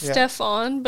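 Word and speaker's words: Stephen: I know, it's Stephon, but it's spelled Steven.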